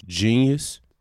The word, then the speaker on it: Jeen-yuhs: Again, it's called Genius. It's spelled J E E N Y U H S. You know what I'm saying? Definitely winning no spelling bees, but it ain't the regular genius spelling is spelt that way.